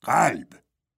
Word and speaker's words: قلب: در کلب. قلب. اون غیر رو مثل که تلفظ میکنه.